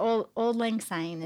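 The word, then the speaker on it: Auld Lang Syne: The old Lang Syne is how that's pronounced.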